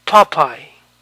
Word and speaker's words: Popeye: Por lo tanto, este nombre de un personaje de dibujos animados se pronuncia Popeye, Popeye.